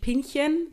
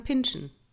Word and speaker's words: pinchen: Es wird Englisch ausgesprochen, also nicht Pinchen, sondern Pinschen.